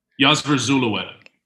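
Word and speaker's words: Yosver Zulueta: Josef Zuleta, And that's not close, is it? – Zulu. They call him. – Yasver Zulueta. – Yasver Zulueta. Oh, that's good.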